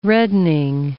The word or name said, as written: reddening